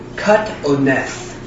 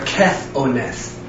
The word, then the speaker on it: katoneth: This Hebrew word, with Strong's number 3801, a theological word study of the Old Testament, number 1058A, is the Hebrew word katoneth. There's two different ways to pronounce it. Katoneth or kethoneth.